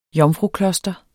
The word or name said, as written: jomfrukloster